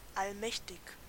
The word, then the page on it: allmächtig: /ʔalˈmɛçtɪç/; /ˈal.mɛːç.tɪç/